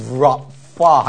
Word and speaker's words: Rafa: Rafa，还记得怎么读吗？还是已经忘光了 Rafa Rafa 这个是 F 的发音 Rafa Rafa 它是什么？